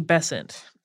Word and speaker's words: Besant: In 1888, the Bryant and May match factories came to the attention of Annie Besant. Most American pronunciations of this seem to rhyme with Crescent, so we're going with that.